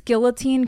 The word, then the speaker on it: guillotine: It's guillotine because this is America and we speak American here.